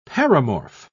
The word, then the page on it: paramorph: British English